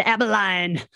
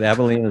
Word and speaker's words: Abilene: Yes, Joe, you mispronounced it the last time. – Last time you said Abilene. – But Abilene is right. – See? Abilene is correct.